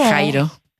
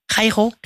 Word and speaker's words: Jairo: Eh bien, comment vous le prononcez, vous? – Jairo. – Jairo. – Jairo. – Ah oui, on a toujours dit Jairo.